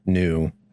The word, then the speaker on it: Nu: They also skipped over NU, which was just before that, because it's pronounced new.